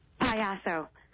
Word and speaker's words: Payaso: How do I say that? – Payaso. – Fatty and Payaso.